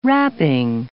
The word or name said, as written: wrapping